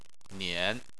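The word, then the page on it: nian: tone 3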